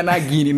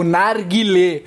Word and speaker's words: Narguilé: Sua pronúncia foi muito ruim, o pessoal pode achar que é Naguilé, não é Naguilé. É o Narguilé.